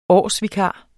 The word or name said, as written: årsvikar